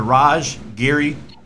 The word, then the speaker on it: Raj Giri: founder and contributor Raj Giri to the show.